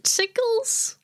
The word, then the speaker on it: sigils: And it turns out that Xemnas decided that he was gonna add all these, they're called Recusant sigils, I think is how you pronounce them. – Sigils. – Recusant.